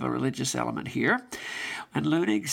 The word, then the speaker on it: Leunig: spelled L-E-U-N-I-G, but I believe it's pronounced Leunig, sort of a popular figure of fresh ways of putting things.